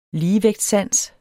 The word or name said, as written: ligevægtssans